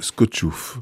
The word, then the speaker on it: Skoczów: Ça s'écrit Skoczów, mais il faut prononcer Skoczów.